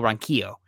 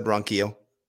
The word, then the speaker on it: Ronquillo: We got to get Michael's name right. Michael Ronquillo. Speaking Spanish. I heard you say Quillo, I thought. – No, I said Ronquillo.